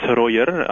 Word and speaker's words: Rojer: It's Roger, R-O-J-E-R, but you were close enough.